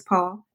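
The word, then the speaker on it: Paule: Is it Paul? Is it just Paul or is the E pronounced? It's Paul. It is Paul.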